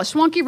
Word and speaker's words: Schwanke: Schwanke rhymes with donkey. So does anybody really like that name uh, anymore? – Yeah, yeah. – Uh. – no, um, Schwanke actually.